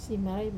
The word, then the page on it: simarai: Wamesa